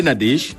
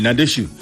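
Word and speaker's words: Nadège: Vendredi 18 septembre, nous fêtons également avec les Nadesh, ou bien les Nadeshu, cela dépend de la prononciation, les Ariane, les Océane également, trois prénoms à l'affiche.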